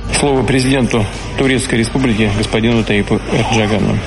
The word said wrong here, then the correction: Ердоган: Реджепу Таїпу Ердогану.